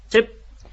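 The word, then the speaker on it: チェㇷ゚: チェップ。 – チェップ。 – チェップ。 – チェップ。 – はい。この最後のチェップという、こう、口を閉じて、発音を、止める感じですね。息を止める感じ。うん、この発音が本当のアイヌ語の発音になります。チェップです。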